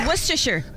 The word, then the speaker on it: Worcestershire: Worcestershire. – Worcestershire. – No, I don't know. – Worcestershire. Is there a shire in it? – Worcestershire. Oh no, it's actually not. It's Worcestershire. That's how you say it.